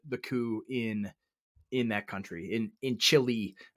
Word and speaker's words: Chile: And so of course, Augusto Pinochet, you have to admire, I pronounce Chile like I do the food. Chili.